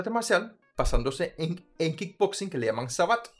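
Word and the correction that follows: Savate: Sabat es el nombre del calzado que usan. Entonces literalmente es un... – ¿Cómo se escribe s a v a t s S-A-V-A-T-E. No, es Sabate. Sabate.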